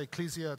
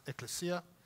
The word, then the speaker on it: ecclesia: Some people say ecclesia. It's actually pronounced ecclesia.